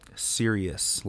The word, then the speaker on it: Sirius: Sirius. Like Sirius. – Like, why so serious? – Why are you so serious?